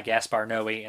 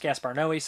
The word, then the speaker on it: Noé: I've always heard it pronounced Gaspar Noé. Some people say Gaspar Noé. I would say Gaspar Noé, but that's me.